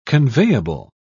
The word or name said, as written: conveyable